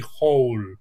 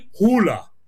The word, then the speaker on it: Hole: Doncs aquest autor de novel·les, que té moltíssimes novel·les de, diguéssim, de sèrie negra, té un personatge que potser, tal com pronunciaries, es pronunciaria potser a l'americana, però no és, per dir-ho clarament, Harry Hole, és Harry Hula.